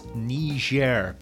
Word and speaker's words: Niger: That's Niger. Be very careful of your pronunciation of that, especially if you're planning on visiting.